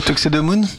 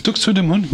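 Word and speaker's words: Tuxedomoon: Moi, la référence, c'est plutôt Tuxedemoon. Je ne sais jamais comment on le prononce. – Tuxedemoon. – Tuxedemoon, oui.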